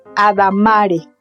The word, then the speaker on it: adamare: De latín adamare, con acento en la A, cortejar, requebrar.